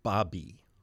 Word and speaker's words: Babi: Continuing the album, it's spelled B-A-B-I, Bobby. And we heard Ba, we heard B, and this piece is called Bobby, WKCR. – Thank you. – That is the album Bobby, B-A-B-I, Bobby, by Milford Graves.